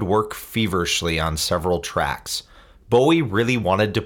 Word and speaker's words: Bowie: I always thought it was Bowie. I thought, well, it's a Scottish name, must be Bowie. But nobody in Scotland pronounces it like that. Pronounce it Bowie.